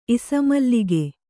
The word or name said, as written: ಇಸಮಲ್ಲಿಗೆ